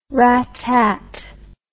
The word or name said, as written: rattat